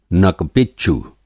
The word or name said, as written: नक-पिच्चू